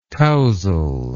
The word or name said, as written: touzle